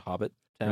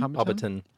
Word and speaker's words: Hobbiton: Went to Hobbit Town. – Really? Nice. – Is that how it's pronounced? – Hobbiton? – Hobbiton.